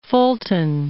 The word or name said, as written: Fulton